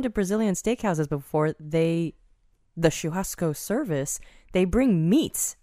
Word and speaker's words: Churrasco: Went to Churrasco. – Yeah, we went to... Oh, you pronounced it right. Mm-hmm. Very well done.